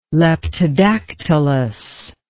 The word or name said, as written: leptodactylous